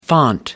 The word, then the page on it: font: American English